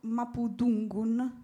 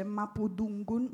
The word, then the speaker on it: Mapudungun: Però appunto una lingua molto difficile che per certi suoni ricorda molto anche l'inglese, perché appunto come vi ho detto Mapudungun non si legge Mapudungun ma Mapudungun con la th, e anche per esempio la r.